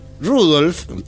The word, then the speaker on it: Rudolf: Claro, Rudolf, que es como se pronunciaba su nombre, Rudolf, dice, bueno, estoy en París bailando.